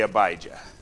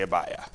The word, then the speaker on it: Abijah: We would say Abijah. They'd say Abia. Okay? And you're just taking out that J there, essentially what you're doing. That's a yah.